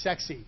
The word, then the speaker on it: CEXC: The first is called CEXC. This is pronounced sexy.